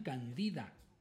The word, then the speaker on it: Candida: Vamos ahora con otra, una canción que todos nos vamos a acordar de ella, se llama Cándida, en inglés decían Candida, ¿verdad? Por su pronunciación.